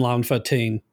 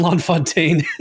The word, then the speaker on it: LaFontaine: Well, for those that are uninitiated, the man behind the movie voice is a guy named Don LaFontaine. I said it wrong. Don LaFontaine.